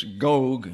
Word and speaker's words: Gog: It's Gog and Magog, actually. There's a long O there.